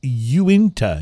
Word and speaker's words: Uinta: It is from, and I went back and looked at the thing, and I was saying it wrong. It's not Unita or Unita. It's Uinta, U-I-N-T-A, Uinta, or maybe it's like Uinta. I don't know. We couldn't find how to pronounce it on the web.